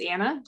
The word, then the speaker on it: Anna: My name's Anna. I live in Indiana, and it rhymes.